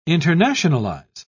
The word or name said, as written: internationalize